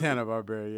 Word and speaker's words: Hanna-Barbera: It's Hanna-Barbera, yes.